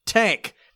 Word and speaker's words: TANGK: next up on the list, Idols, Tank. It's spelled T-A-N-G-K, which you would pronounce tank.